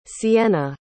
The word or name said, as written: siena